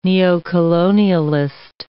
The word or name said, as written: neocolonialist